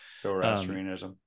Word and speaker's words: Zoroastrianism: Zoroastrianism.